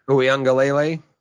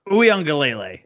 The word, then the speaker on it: Uiagalelei: DJ Uiagalelei. I always screw up his name. – Uiagalelei. – Uiagalelei. That's right. I always forget Beautiful. That extra syllable.